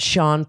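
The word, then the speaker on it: Shawn: You can find me at the convenient name of at Sean Pelofsky. – Better spell that last name. – Yeah, P-E-L-O-F-S-K-Y, and it's S-H-A-W-N.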